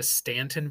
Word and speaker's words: Staunton: That's spelled S T A U N T O N. Uh, I was told that they actually named it that or spelled it that way so they could tell who was from here and who wasn't. So if you said, Oh, I'm, I'm from Staunton, they would know that you weren't because you're saying it incorrectly.